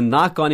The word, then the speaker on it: knock: Experts say that even if the strike winds down soon, soon é logo, S-O-O-N, the knock-on effects, eu vou continuar a sentença, the knock-on effects, knock, eu estou batendo aqui na mesa, né? Knock, K-N-O-C-K, não pronuncie esse o primeiro K, tá?